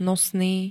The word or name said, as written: nosný